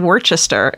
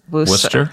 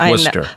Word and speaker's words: Worcester: I said Worcester instead of Worcester on the show. – Yeah. – It's Worcester.